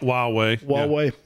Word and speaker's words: Huawei: So Huawei, right? Is that how you pronounce it? – Huawei.